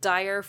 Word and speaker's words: Dyer: Now, I have to ask, just right off the bat, did you pick Dyer for Noah's last name because it sounds like the word D-I-R-E, as in bad times are coming? – Exactly that, yes.